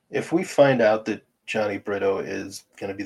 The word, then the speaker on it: Jhony: It's spelled J-H-O-N-Y. So I call him Johnny.